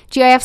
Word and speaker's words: GIF: Oh, the proper pronunciation of the acronym is a soft G. So it should be said GIF.